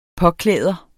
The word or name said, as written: påklæder